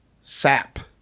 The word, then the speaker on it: Psapp: Sapp. P-S-A-P-P. It is with a silent P.